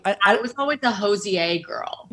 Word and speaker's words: Hozier: We were talking about that someone said his name is Hozier. That's how you pronounce it. It's Hozier.